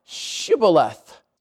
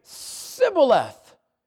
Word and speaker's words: Shibboleth: If he said no, not the enemy, verse number 6, then said they unto them, say now Shibboleth. And he would say, Sibboleth, for he could not frame to pronounce it right.